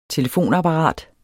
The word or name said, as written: telefonapparat